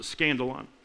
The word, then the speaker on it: skandalon: it's a word called skandalon. That's how you pronounce it, skandalon.